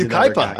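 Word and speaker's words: Yucaipa: He's Ukaipa, and I know how to pronounce that too, because yes, I lived in Ukaipa.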